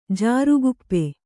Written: ಜಾರುಗುಪ್ಪೆ

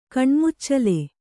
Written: ಕಣ್ಮುಚ್ಚಲೆ

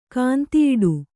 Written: ಕಾಂತಿಯಿಡು

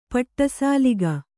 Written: ಪಡಿಸಲಿಕೆ

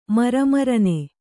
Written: ಮರಮರನೆ

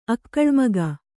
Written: ಅಕ್ಕಳ್ಮಗ

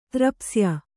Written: ತ್ರಪ್ಸ್ಯ

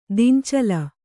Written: ದಿಂಚಲ

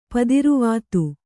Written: ಪುತ್ರವಧು

